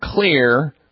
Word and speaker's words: Xlear: And the one that I use personally and recommend is something called Clear Rescue Nasal Spray. Clear is spelled X-L-E-A-R.